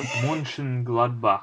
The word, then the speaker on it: Mönchengladbach: So yeah, I saw that how do you say it, Mönchengladbach, the German club, Mönchengladbach.